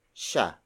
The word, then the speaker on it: siya: Siya. Sino? Siya. Sino? Siya. Sino means who. And then siya, siya, siya is spelled as S I Y A.